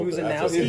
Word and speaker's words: Muszanowski: Did I say it right? – No. Muszanowski.